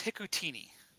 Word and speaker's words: Hikutini: Cole Hakatuni. Did I say that correctly? – I think it's Hikutini. – Hikutini.